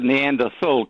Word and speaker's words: Neanderthal: You can pronounce it Neanderthal because it finishes T-H-A-L. Both ways are correct.